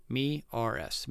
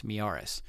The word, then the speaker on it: Miyares: It's spelled M-I-Y-A-R-E-S, Miaris, Miaris.